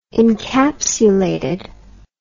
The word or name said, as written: encapsulated